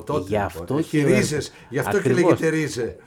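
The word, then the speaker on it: Rize: Και ρίζε το προφέρουν τώρα.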